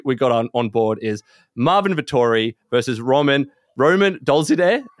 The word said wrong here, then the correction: Dolidze: It's like Dolidze? – Dolidze. That's 100%.